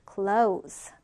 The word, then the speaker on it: close: 자, 이렇게 Z 발음으로 동사할 때는 Z 발음으로 발음한다라고 했죠. close.